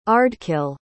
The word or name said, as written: ardkill